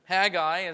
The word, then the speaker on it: Haggai: And the book of Haggai, some call it Haggai. It's okay, everyone has a right to be wrong. Haggai is the phonetic pronunciation, we'll go with that today. You can pronounce it differently.